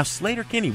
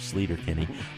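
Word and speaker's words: Sleater-Kinney: Now, Slater-Kinney, we did our research, Greg. This apparently is the correct pronunciation for the off-ramp in Olympia on the highway that originally gave the group its name. We've been saying it wrong for 20 years, Slater-Kinney.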